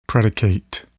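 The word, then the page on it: predicate: verb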